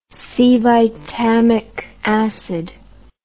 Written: cevitamic acid

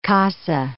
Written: casa

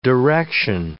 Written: direction

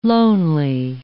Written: lonely

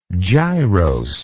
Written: gyrose

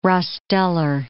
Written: rostellar

